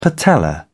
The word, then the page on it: patella: British English